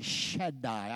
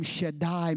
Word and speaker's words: Shaddai: The name Almighty is the Hebrew name Shaddai, S-H-A-D-D-A-I, Shaddai. I guess that's how you pronounce it.